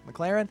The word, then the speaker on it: McLaren: McLaren, is that how you say it? – Yes. – McLaren.